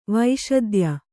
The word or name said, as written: ವಿಶೋಧ್ಯ